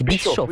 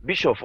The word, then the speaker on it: Bischoff: C'est Bischoff. Oui, c'est Bischoff, on prononce « ch », il n'y a pas besoin de séparer, c'est okay. SCH et « ch » en allemand, Bischoff, oui.